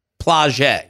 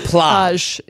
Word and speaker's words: plage: Because it's like when I mispronounced plage. Yes. Instead of plage. – Yes. – Plage is beach in French.